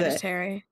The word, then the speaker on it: Hereditary: I've seen the synopsis of It Follows and Hereditary or Heredity, whatever, however you pronounce Hereditary.